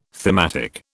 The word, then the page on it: thematic: British English